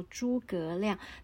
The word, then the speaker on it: 诸葛亮: 那讲到这个“ 三顾茅庐”，肯定想得到诸葛亮。